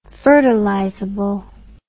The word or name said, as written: fertilizability